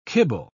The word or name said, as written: kibble